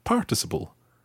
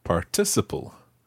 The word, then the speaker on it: participle: Now, those of you who have heard this word before, particularly in the States, will probably know it as participle. In the UK, we pronounce it stressing the second syllable, participle.